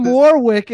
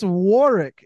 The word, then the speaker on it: Warwick: You keep calling him Warwick. It's Warwick.